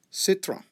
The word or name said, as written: citron